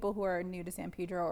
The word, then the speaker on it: San Pedro: Just say San Pedro. That's it. Mm-hmm. Don't ask any questions. Just say San Pedro. – Just do it. – The long E.